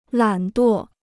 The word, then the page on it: 懒惰: lǎn duò